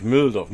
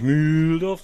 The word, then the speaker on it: Mühldorf: für dich Mülldorf. Nicht Mühldorf, sondern Mülldorf. Was aber nicht Also. – mit Doppel-L geschrieben wird wie Müll, sondern einfach nur L.